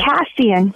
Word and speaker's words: Cassian: Cassian.